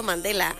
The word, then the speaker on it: Mandela: Efecto Mandala, efecto Mandela.